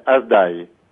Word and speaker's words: Azdai: So we believe since then, like our name is sometimes called Yazidi. But original pronunciation is Azdai, which is from the word of Malek Tawis.